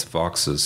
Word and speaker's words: Vaux: Well, first of all, it's Vaux's swift. – Thank you. It was V-A-U-X, and I went for the fancy pronunciation. – Everybody does. – Sorry. – But the guy's last name was Vaux, okay.